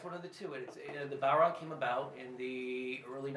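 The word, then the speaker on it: bodhrán: The actual name of it is the Balron. – Balron. – Balron, I guess, is the easiest way to pronounce it, which is, it's spelled B-O-D-H-R-A-N. You get Balron out of that. So, it's the Irish language and the pronunciation.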